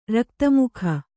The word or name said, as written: ರಕ್ತಮುಖ